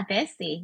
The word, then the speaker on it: Desi: And then there was a word that I learned through reading the book called Desi. – Desi. Yeah, no, you got it. Yeah, Desi. Yeah. – I've been trying to listen to, you know, the pronunciation on Google. It's spelled D-E-S-I.